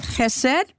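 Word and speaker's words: chesed (חסד): Now, the book that we're offering, it spells it H-E-S-E-D, but it can be with a dot under it. Then people who know this rule will pronounce this with the ch sound for chesed. So, hallelujah, bless the Lord. So, we're studying chesed, and hallelujah, December 6th, I turned 85 years old.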